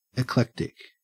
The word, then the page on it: eclectic: Australian English